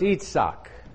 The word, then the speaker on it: Yitzhak: In Yitzhak shall your seed be called. In Isaac shall your seed be called. It's pronounced Yitzhak.